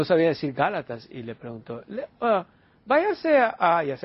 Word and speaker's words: Gálatas: Y luego le dijo el pastor, Gálatas, le dijo así con la voz que tenía, Gálatas.